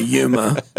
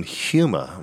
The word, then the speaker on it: Yuma: I recently went to, I'm going to say it correctly, Robert, to Yuma. – Chad kept saying Huma. I'm like, what? I give a little... – Why are you saying Huma? – So I got a friend.